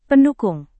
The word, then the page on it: pendukung: Indonesian